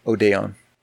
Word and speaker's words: Odeon: Where's the accent? – Brazil. It's no accent on it, so it's frustrating. – Hard to tell. – So it would be on the second syllable, Odeon.